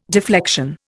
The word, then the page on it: deflection: Indian